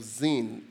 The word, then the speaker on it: Zin: And today we come to a place called the wilderness of Zin. Zin is spelled Z-I-N. It's slightly different from the second wilderness that we talked about, which is Sin, S-I-N.